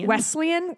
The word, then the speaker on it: Wesleyan: I can never say that correctly. – Wesleyan. – I want an L to be in there. Wesleyan.